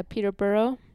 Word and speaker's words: Peterborough: So we went to Peterborough, is that how you say it? Peterborough? – Yeah, Peterborough.